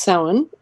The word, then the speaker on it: Samhain: And it looks like Samhain, but it's pronounced Samhain.